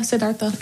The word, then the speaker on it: Siddhartha: I was thinking about when they talked about Buddhism and they said that when Siddhartha, is that how you say it? – Yeah, Siddhartha.